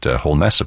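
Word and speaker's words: Holmès: Looks like Holmes, like Sherlock Holmes, but she has the accent over the E and pronounced it Holmes, apparently.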